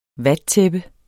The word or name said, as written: vattæppe